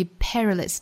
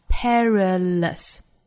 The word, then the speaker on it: perilous: 后面 thought without learning is perilous，我们尤其要注意 perilous 这个词末尾呢，不是卷舌的二音，而同样是刷 sound，的发音 perilous。